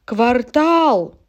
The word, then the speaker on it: квартал: Квартал. Помните, районы, кварталы, жилые массивы. Я ухожу, ухожу красиво. По этой строчке группы «Звери» песни можно запомнить это слово «квартал». Другого ударения у этого слова просто нет.